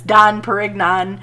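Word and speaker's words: Dom Pérignon: Dom Perignon. And that's how it's said later on in the movie.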